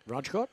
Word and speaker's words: Rajkot: Mark Wood is replacing Bashir in England's only change for the third test against India, starting in Rajkot. Have I pronounced that correctly? – Rajkot? – Yep.